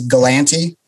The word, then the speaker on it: Galante: And I always say, like, the way you pronounce my last name is Galante.